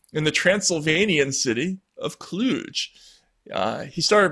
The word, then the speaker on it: Cluj: Cluj. – Cluj, Romania.